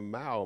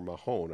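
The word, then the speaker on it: Mahón: You didn't go into the main town of Mau, Menorca, or Mahon, I guess is how a lot of people pronounce it.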